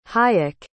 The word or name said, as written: hayek